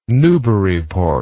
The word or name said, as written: newburyport